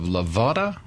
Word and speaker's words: Lavada: We're running a little short on time, but from Natchez we have Lavada. Did I pronounce that right? – Yes, hello, Lavada.